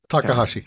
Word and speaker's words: Takahashi: and I want to make sure I pronounce this right to be respectful, Kazuki Takahashi? – Yes, Takahashi. – Sorry. I had to take it slow. So Kazuki Takahashi.